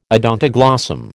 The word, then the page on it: odontoglossum: American English